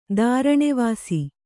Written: ದಾರಣೆವಾಸಿ

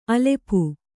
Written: ಅಲ್ಪ

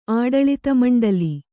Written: ಆಡಳಿತಮಂಡಲಿ

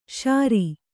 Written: ಶಿರ

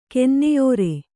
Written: ಕೆನ್ನೆಯೋರೆ

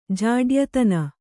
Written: ಜಾಡ್ಯತನ

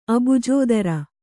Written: ಅಬ್ಜೋದರ

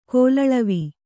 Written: ಕೋಲಳವಿ

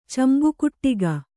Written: ಚಂಬುಕುಟ್ಟಗ

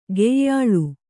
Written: ಕೊಯ್ಯಾಳ್

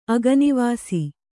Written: ಅಗನಿವಾಸಿ

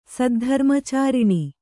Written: ಸದ್ಧರ್ಮಚಾರಿಣಿ

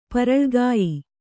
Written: ಪರಳುಗಾಯಿ

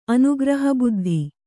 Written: ಅನುಗ್ರಹಬುದ್ಧಿ

